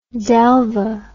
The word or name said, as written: Delvaux